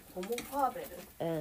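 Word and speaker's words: ホモ・ファーベル: ホモファーベル、うん、道具人と書いてホモファーベルと読む、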